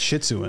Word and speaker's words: Shih Tzu: He's got that shih tzu in him. – You said that right.